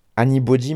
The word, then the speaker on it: Anybxdy: Alors je pense que c'est Annie Body, mais en fait ça s'écrit Annie BXDY.